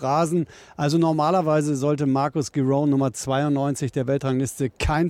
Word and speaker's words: Giron: Wer bitte ist Marcus Giron? Giron? – Ja, er spricht sich Giron aus tatsächlich